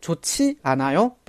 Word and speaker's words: 좋지 않아요: 这个地方的话呢，发音有一个注意点， 좋 지 않 아 요，本来是 지 않 아 요，但是因为前面 조 呃收音影响，会读成 좋 지 않 아 요，不好吗？